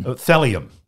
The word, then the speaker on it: thallium: Thallium rhymes with valium, but it's rat poison.